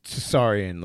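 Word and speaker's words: Tsarion: Do you pronounce the T there? Or is it just Tsarian? – It's Tsarian, like.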